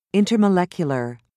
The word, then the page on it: intermolecular: British English